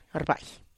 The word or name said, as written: rrbaih